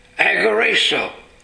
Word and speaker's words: agorazo: First of all, there's a Greek word that is spelled A-G-O-R-A-Z-O, agorazo.